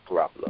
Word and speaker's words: Garoppolo: Jimmy Garoppolo? – Garoppolo. – That sound about right? – Yeah, Garoppolo.